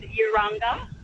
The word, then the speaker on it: Urunga: I'm just driving past Mala, and I thought I should call in and say it's pronounced Mala and Urunga and Ginnigayway.